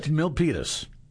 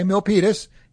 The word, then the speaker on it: Milpitas: Milpitas. – Okay, Milpitas.